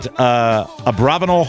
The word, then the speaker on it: Abravanel: Drew is currently the technical director at, is it Abravanel Hall? Is that the correct pronunciation? – That's right.